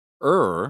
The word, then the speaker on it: Ur: the family moved from Ur, er, I believe is how you pronounce it, spelt U R, but in what is now southern, it's now considered southern Iraq, some 600 miles closer to Canaan, settling in Haran.